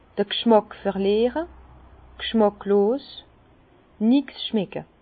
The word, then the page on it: d'r G'schmàck verliere, g'schmàcklos, nix schmecke: Bas-Rhin; Herrlisheim